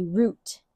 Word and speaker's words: Ruth: And the way you pronounce it is actually root, like ROOT, root. And it's spelled RUT.